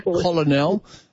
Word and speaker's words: colonel: Colonel, from French, you know, that even though it's pronounced K-E-R-N-E-L, it's spelled colonel.